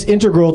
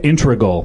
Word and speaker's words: integral: Dino doing a voiceover said integral, which is a big pet peeve of mine. – Right. – Integral, because people say that all the time.